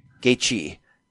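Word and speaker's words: Gaethje: Gagey.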